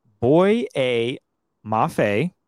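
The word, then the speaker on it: Boye Mafe: And that would be Boy A Mafe.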